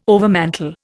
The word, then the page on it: overmantel: Indian